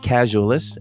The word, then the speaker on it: Casualyst: I hope you will follow me on Twitter at Casualyst, and that's spelled a little oddly, C-A-S-U-A-L-Y-S-T, Casualyst, as in Catalyst, Casualyst, you got it.